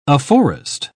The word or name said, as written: afforest